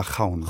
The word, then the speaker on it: Ravn: wird geschrieben Olga Raven. Ausgesprochen wird es Olga. Olga Chaun. Chaun.